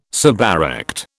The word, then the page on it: suberect: British English